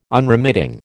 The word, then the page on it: Unremitting: American English